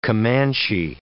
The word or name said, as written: Comanche